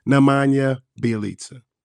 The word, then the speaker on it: Nemanja Bjelica: I think I got it right. Nemanja Bjelica.